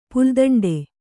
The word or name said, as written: ಪುಲ್ದಂಡೆ